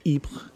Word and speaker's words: Ypres: And they ended up at a city called Ypres in Belgium, or Ypres, if you're using the French pronunciation.